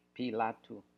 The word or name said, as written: Pilatou